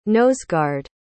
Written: noseguard

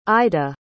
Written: aida

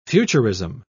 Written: futurism

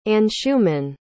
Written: anshuman

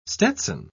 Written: stetson

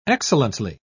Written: excellently

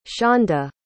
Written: shonda

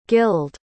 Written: gilled

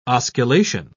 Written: osculation

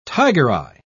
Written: tigereye

